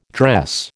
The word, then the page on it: dress: American English